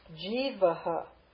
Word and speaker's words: jivaha: Jivaha. Do you see the h with the dot? Look up the Sanskrit. Do you see those two dots? That's the H with the dot. It's called a visarga. So that visarga is going to aspirate the preceding letter. The preceding letter is a. So G, long E, jivaha. Jivaha.